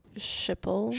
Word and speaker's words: Schiphol: Schiphol.